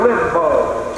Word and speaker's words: Limbo: The fourth place is called Limbo, L-I-M-B-U-S, and it's often pronounced Limbo, L-I-M-B-O.